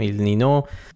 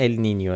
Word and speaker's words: ال نینیو: ولی تلفظ درست اگه میخواین ال نینیو، ولی همه جا تو فارسی به چرخید کسی چیکی نمیشه ال نینیو، همه جا نوشتن ال نینو.